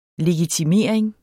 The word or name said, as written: legitimering